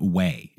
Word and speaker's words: OUAI: Let me tell you about Way. It's spelled O-U-A-I.